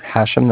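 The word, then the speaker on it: Hashem: Yes, this is Hashim. – I'm sorry, could you spell it for me? – Yes, sure. Hashim, that's H-A-S-H-E-M.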